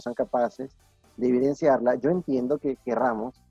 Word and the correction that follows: queramos: o queramos, perdón, no sé cómo se dice la palabra correcta.